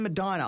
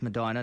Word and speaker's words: Medina: His house in Medina, I believe that's how it is pronounced, Medina, not Medina, as I used to do it when I got to the States. Oh. I was politely corrected. It's Medina.